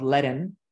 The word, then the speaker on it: Ledn: So for example, a company that we invested out of our fund one, a company called Ledin, and it's spelled L-E-D-N. It's the play on words on HODL and lending.